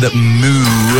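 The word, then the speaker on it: MØ: Kiss with an absolute serve from Major Lazer, DJ Snake, and Moo. That's how you say her name, by the way. It's Moo. Moo. Get that? Moo.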